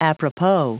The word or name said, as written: apropos